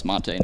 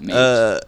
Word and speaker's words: Mate: I believe it's pronounced Mate, not Mate.